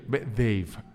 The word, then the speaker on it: Dave: Dave, ese se pronuncia Dave, porque es con B la dental.